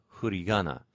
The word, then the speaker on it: furigana: The kanji characters, the little characters are called hurigana, which is, of course, written F-U-R-I-G-A-N-A, and that huri is the bilabial fricative.